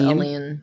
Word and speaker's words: Aline: Um, and how do you say her name? Uh, her name? – Aline. Aline? I think Aline? it's Aline. Okay.